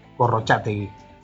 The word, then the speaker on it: Gorrochategui: Sí, en, realidad mi padre era de San Sebastián, pero yo ya nací en La Coruña y bueno, eh, siempre me he llamado Gorrochategui, creo que en vasco se pronunciaría Gorrochategui. – Yo me llamo ah. Gorrochategui, pero bueno, eh, estoy encantado de mi, herencia vasca y, se pronuncie como se, pronuncia, está bien pronunciado.